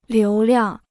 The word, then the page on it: 流量: liú liàng